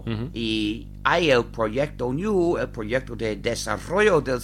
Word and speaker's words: GNU: Se escribe GNU. En inglés se pronuncia GNU, pero en castellano se pronuncia GNU.